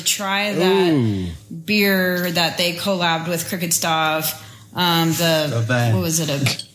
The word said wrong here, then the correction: Stave: It's pronounced Stave.